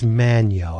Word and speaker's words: Magno: And and Carlos, is it Magno? – I think it's Manio. I think that Manio. Like me, the G is silent.